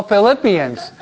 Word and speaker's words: Philippians: Oh, Philippians. I mispronounced it. I'm waiting for you to correct me, okay? Okay. The letter of St. Paul to the Philippians, okay.